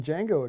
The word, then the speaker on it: Django: So good that you're saying Django again. – I know. I kept saying the Django for the longest time. – The D is silent.